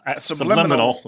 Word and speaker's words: subliminal: That is, as I was saying this, that is nuts, and that is subliminable. – Subliminal. You added subliminal. Extra syllable.